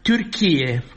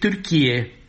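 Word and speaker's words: Türkiye: au evitat grijuliu să rostească numele englezesc al Turciei, Turkey, și au pronunțat sistematic doar Turchie, chiar dacă cu accent greșit.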